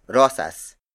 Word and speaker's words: rosas: The last non-native Tagalog color is rosas.